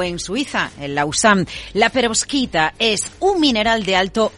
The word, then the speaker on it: perovskita: Bueno, esto tiene que ver con Asia, esta historia, que es una de las más llamativas de esta mañana, la perovskita. Lo he dicho bien. – Lo has dicho perfectamente.